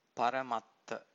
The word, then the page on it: paramatta: Pali